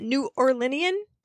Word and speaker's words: New Orleanian: And there were all shades of skin at these meetings, a particularly New Orleanian, wait, how do I say that? New Orleanian? Is that the word? – Yes, that was very good.